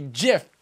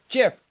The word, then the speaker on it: GIF: Okay, so this man, Johnny Smartass over here, this man has said that it, it, those using the hard G are wrong. End of story. So it's a GIF. It's a soft G. GIF, GIF, GIF.